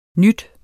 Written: ny

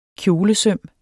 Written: kjolesøm

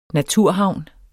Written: naturhavn

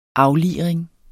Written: afliring

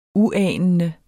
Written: uanende

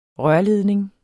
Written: rørledning

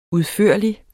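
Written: UDFØRLIGSTE

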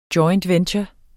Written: joint

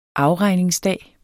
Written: afregningsdag

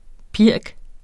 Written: pirk

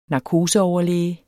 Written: narkoseoverlæge

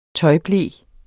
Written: tøjble

